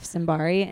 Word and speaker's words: Simbari: I'm at Steph Simbari and my last name is spelled like Simba from The Lion King with an R-I at the end.